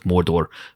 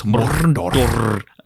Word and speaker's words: Mordor: And I loved, and I know it's the canonical pronunciation of Mordor, but she goes Mordor, and it just made me laugh.